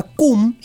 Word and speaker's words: Quum: Estamos hablando de una agrupación muy peculiar llamada CUM, se escribe con Q, dos u y una M,